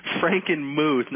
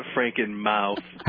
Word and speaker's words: Frankenmuth: Frankenmuth, not Frankenmouth. – How is it spelled? – How is it spelled? I don't know. I grew up by there, so I know it's pronounced Frankenmuth.